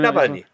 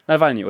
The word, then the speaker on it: Nawalny: Ist das eigentlich die korrekte ja. Aussprache? Ich bin Nawalny. – Nawalny, oder? Genau. Ja.